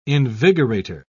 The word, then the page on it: invigorator: British English